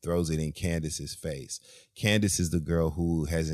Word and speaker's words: Candiace: It's C-A-N-D-I-A-C-E. But you pronounce it Candace, not Candiace or whatever. – Oh. – It's just Candace.